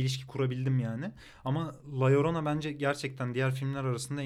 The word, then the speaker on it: La Llorona: Ayrıca Guatemala'nın adayı da yanlış da telaffuz etmek istemiyorum. La Llorona. – İzledim. La Llorona. La Llorona. Öyleymiş yani. Öğrendim. Ben de öğrendim. La Llorona.